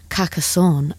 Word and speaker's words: Carcassonne: They went to see Franz Ferdinand perform, and I have looked up how to say this, at the Festival de Carcassonne. Apparently, that's how you say it, according to Google, on Monday evening.